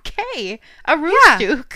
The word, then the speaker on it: Aroostook: It's spelled A-R-O-O. Aru, right? – Yeah. – S-T. Stu, right? – Yeah. – O-O. Ooh. – Yeah. O-O. Ooh. – K. Aroostook. Yeah.